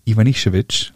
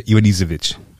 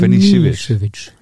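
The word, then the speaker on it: Ivanišević: Der doofe Deutsche sagt ja Ivanisevic. Ja. Oder, oder noch schlimmer Ivanisevic. – Ivanisevic, das ist das Allerschlimmste aber Ivan, Ivan Nischewitsch.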